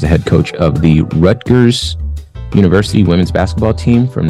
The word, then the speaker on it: Rutgers: Rutgers, that's it.